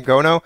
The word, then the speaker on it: Gono: Is that how you pronounce his name, Gono? – Yeah.